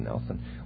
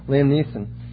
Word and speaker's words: Neeson: Is that how you say his name? I always mess that up. I always want to say Nelson. Liam Neeson.